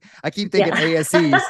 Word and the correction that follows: Asé: So that's how I've been saying it this whole time. Ashe.